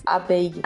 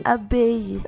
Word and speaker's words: abeille: Abeille. – Abeille. Ah, que coisa linda. – É como se tivesse outro I. – Entendi. Então não tem L. – Abeille. – Abeille.